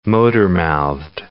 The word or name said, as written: motormouthed